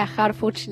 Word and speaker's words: Harfuch: Bueno, la de Instagram es Leila Harfuch Lacase.